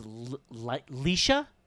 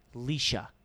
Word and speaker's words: Lycia: Actress, I'm going to pronounce it as Leisha. It's L-Y-C-I-A. Leisha? – L-Y-C-I-A. – Leisha. – Okay, that makes sense, yeah.